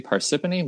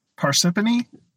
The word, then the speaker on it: Parsippany: that goes very counter to general English pronunciation rules. I mean, I've never heard it pronounced, so it might be Parsippany. But that Parsippany, the fact that there's two P's there makes me really suspect that it's the second syllable that carries the primary stress on Parsippany.